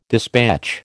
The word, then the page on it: dispatch: American English